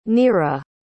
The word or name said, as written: nira